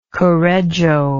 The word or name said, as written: Correggio